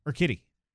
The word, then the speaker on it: Urquidy: Urquidy.